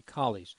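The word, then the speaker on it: Colles: So the pronunciation should be Coles.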